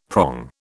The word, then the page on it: prong: British English